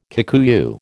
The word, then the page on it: Kikuyu: American English